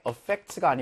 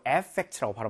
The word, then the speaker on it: effect: affect가 아니고 effect라고 발음하거든요. effect. 여기에 강세가 있어요.